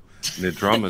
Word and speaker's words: Nidrama: Nidrama is correct.